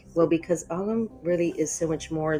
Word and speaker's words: Ogham: Ogham, spelled O-G-A-M and pronounced Ogham, which is a more modern way of pronouncing it. Ogham is the ancient Irish way of pronouncing it, where it's O-G-H-A-M and the H makes the G silent. But there's a terrific book by Aaron Rowan Laurie called Ogham, O-G-A-M, Weaving Word Wisdom.